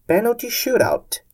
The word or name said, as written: penalty shootout